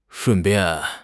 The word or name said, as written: shun4 bian4